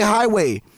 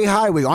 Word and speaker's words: Hiiway: It's available on YouTube, which is a dope video, and you can find your boy Ray Hiiway. Ray Hiiway on Instagram.